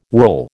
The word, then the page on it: whorl: American English